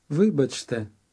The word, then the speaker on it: вибачте: Вибачте.